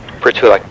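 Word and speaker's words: Prytulak: Pritulak, P-R-Y. – P-R-Y? – T-U. – T as in Tom, U? – Right. L-A-K. – L-A-K? – Right. – And that's pronounced Pritulak? – Yeah. Mm-hmm.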